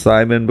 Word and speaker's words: Psimon: This is Psimon, so it's Simon but with a P.